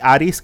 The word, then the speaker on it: Arisu: Eh, se escribe como Arisu, o en los subtítulos le ponen Arisu, pero en eh, japonés cuando le hablan le dicen Aris.